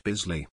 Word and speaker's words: Bisley: He and like four other friends, I think one's Grizzly, one's Brixley, I said that wrong, and one's, insert name. – Condifiction. Also, Mac, it's pronounced Bisley.